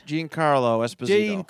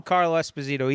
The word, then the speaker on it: Giancarlo Esposito: Giancarlo Esposito. – Giancarlo Esposito.